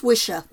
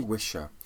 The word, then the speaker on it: Wicca: It's pronounced Wisher. – It was originally Wisher,